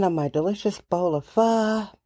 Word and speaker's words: pho: Hey, people, it's pronounced pho, not pho.